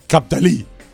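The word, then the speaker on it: Cap d'Ail: Cap d'Ali, France. Baton, did I say it right today? – Cap d'Ali. – I did.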